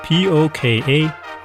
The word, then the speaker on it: poka-yoke: P-O-K-A-Y-O-K-E. Poka-yoke. When you see this Japanese origin word written out in English, it looks kind of like poka-yoke. But don't say poka-yoke because that's really off. Pronounce it more like the Japanese, sort of like poka-yoke, or poka-yoke if you want to say more Japanese sounding, but that's just weird in English.